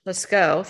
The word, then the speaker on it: Lascaux: And I've read a little bit about the Lascaux. How do you say it? – Lascaux. – Thing. Oh my gosh. – Lascaux Caves. – You know, the third way to say it.